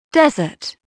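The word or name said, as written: desert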